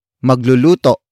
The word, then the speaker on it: magluluto: For example, magluluto pa lamang ako. Magluluto pa lamang ako. Magluluto pa lamang ako. It means, I am just about to cook. Word for word, it's magluluto pa lamang ako.